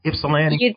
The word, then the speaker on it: Ypsilanti: Did I say it right, Ypsilanti? – You did very well.